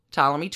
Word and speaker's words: Ptolemy: She attempts to take her daddy, Ptolemy 12, out of the running. It's pretty bad. – Permanent silent P.